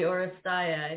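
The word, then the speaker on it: Oresteia: Oristeo? – The Oristeia.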